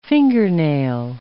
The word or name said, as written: fingernail